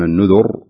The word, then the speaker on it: النذر: على سبيل المثال في قوله تعالى: آل فرعون النذر، هنا تفخم الراء، ما يقول الإنسان نذر، لا، النذر مقتدر، هنا ترقق الراء لأن الراء ساكن وما قبلها مكسور.